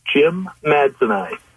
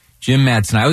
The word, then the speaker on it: jimmadseni: It's Allosaurus Jim Madsenai. – Jim I. Jim Madsenai.